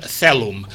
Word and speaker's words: coelum: Per començar, la O i la E ja des de molt antic, des de l'edat mitjana es pronuncia una E. Celum.